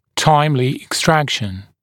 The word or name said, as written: timely extraction